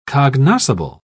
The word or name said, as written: cognoscible